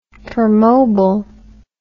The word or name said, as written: permobil